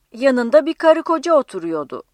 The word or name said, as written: otur-